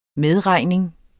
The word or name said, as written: medregning